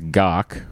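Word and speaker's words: gagh: Gog, pronounced Gok.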